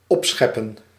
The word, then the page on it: angeben: /ˈʔanɡeːbm̩/; /ˈʔanɡeːbən/